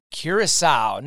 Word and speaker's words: Curaçao: I want to introduce everybody to Chandra Smaus, who is International Liaison for North America for the island of Curaçao.